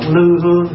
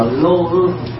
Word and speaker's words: lô hương: Thay vì mình tụng lư hương xạ nhiệt, mà tiếng ngày xưa không có đọc lư hương mà đọc là lô hương.